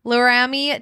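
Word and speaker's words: Laremy: Amari Cooper, Laramie Tunsil. I said it right the first time. Laramie, like Jeremy Tunsil, Houston offensive tackle.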